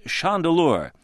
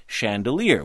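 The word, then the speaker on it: Chandeleur: We checked with our reporter on that story, Kathy Lohr, and she says that the local residents she spoke with all pronounce it chandelier.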